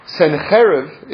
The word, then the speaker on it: Sennacherib: Sennacherib is how we pronounce it in Hebrew.